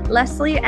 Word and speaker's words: Lesley: If we aren't yet connected on Instagram, come follow me at Leslie & Co.